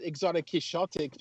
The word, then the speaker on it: Exotic Quixotic: Exotic Quizotic? Is that how you pronounce that? – I'm unsure of that one myself. I call it Exotic Quixotic because of Don Quixote, but, you know.